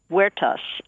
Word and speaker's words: Huertas: The name of the restaurant is Huertas. It's a Spanish name. It means garden. It's spelled with an H because the H is silent in Spanish.